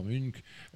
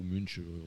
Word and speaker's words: Munch: Et il y a un chapitre entier, parce que ça me semblait essentiel, sur cette fameuse toile qui s'appelle le cri d'Edouard Munch, ou Munch, on peut prononcer.